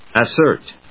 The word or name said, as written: assert